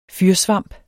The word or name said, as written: fyrsvamp